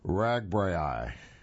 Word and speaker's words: RAGBRAI: RAGBRAI is how I'll pronounce it, just to shorten things.